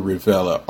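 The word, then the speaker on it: Revelia: I'm going to spell his name for you because I know I probably didn't say it right. It's Paul Rivella, R-E-V-E-L-I-A. I think that's an I. Yeah, L-I-A.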